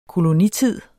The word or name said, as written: kolonitid